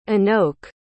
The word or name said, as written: inoke